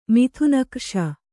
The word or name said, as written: ಮಿಥುನಕ್ರ್ಷ